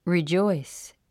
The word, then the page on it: rejoice: ridʒɔ́is; リジョイス